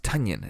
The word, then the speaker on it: Tonyan: I found out the other week that it is pronounced Tonyan and not Tonyan, like it's spelled.